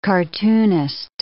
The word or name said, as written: cartoonist